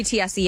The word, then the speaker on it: Tiesi: How do you say is it Tiesi? Bree Tiesi? – Yeah, Bree Tiesi, yep.